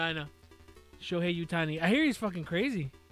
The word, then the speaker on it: Shohei Ohtani: And it's Shohei Ohtani.